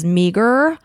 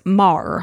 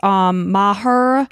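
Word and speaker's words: Meagher: So I pronounced it in my head when I read it as Meager, but Jill is not actually Australian.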